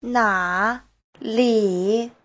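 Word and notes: 哪里: na3li3

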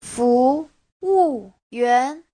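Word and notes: fuwuyuan: fu2wu4yuan2

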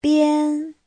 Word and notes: bian: bian1

